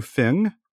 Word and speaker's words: Feng: It's a translation by, and pardon my pronunciation, Guy Fu Feng, F-E-N-G, and Jane English. That's her last name. It has nothing to do with an English translation, but it's the Feng, F-E-N-G, Feng English translation.